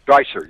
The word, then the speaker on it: Dreiser: How do you say that? Dreiser? Dreiser, yeah. Dreiser, yeah.